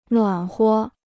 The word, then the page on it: 暖和: nuǎn huo